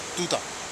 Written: tuta

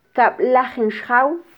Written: tkablajin xjaw

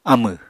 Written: amẁ

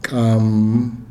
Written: kam

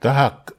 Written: tahak

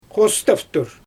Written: xostaftur

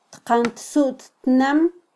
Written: tqantsuttnam

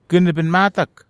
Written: ken nepen matak

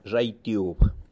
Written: Xaitiub

